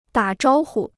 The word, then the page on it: 打招呼: dǎ zhāo hu